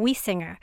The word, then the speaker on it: Wiessinger: So today I'm really happy to have on the show Diane Wiesinger, and it's the way she says to pronounce her name is think of singing. I sing, you sing, Wiesinger.